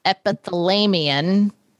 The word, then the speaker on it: epithalamion: Diane is saying her word for the day is epithalamion.